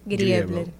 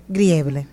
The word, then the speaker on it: Griebler: Ayúdame con tu apellido. Griebler. Griebler. – Tal cual como se escribe.